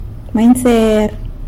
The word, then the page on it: manyser: sanlucasquiavini